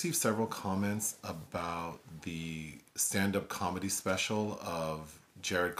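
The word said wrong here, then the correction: Jerrod: Carmichael. – It's pronounced Gerard.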